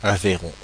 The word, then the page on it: Aveyron: [a.ve.ʁɔ̃]